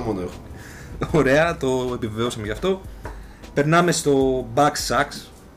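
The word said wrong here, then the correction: Bugsnax: Αν το προφέρω σωστά, Back Sacks λεγότανε. Ε, ένα παιδικό ήταν εκεί πέρα με mm. κάτι. Bug snacks, ναι.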